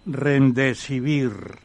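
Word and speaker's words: Remdesivir: Rendesivir de Rende De lo has dicho bien.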